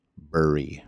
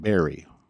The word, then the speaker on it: Burry: Burry, B-U-R-R-Y, not Barry.